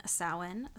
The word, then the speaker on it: Samhain: Listen to our episode on Samhain, spelled yes.